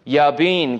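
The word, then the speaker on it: Jabin: I'm going to say his name like that. Yabin, king of Canaan, who reigned in Hazor.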